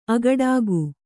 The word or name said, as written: ಆಗಡಿಗ